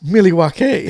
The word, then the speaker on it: Milwaukee: You know, it's actually pronounced Milwaukee, which is Algonquin for the good land.